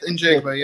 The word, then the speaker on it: Njigba: It's Jackson Smith Njigba.